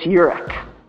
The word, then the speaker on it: Eurich: It's Eurich.